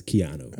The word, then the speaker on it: Keanu: So are we going with Kino or Keanu? I don't know how smooth it is. – I I in the piece, it's actually phonetically pronounced. – Ah, you're right. – It is. So I am 90% sure I'm wrong, that it is Keanu.